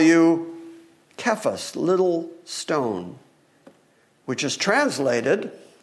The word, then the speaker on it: Kephas: You shall be called Kephas. Oftentimes we pronounce that Cephas, but in the Greek language it's a K, it's a hard sound.